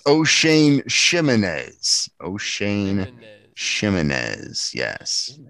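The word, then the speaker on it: Ximines: O'Shane Chimneys. – Chimneys, yes.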